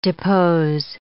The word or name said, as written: depose